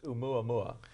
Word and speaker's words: Oumuamua: Very simple to pronounce, actually. It's just Oumuamua. It's a Hawaiian word.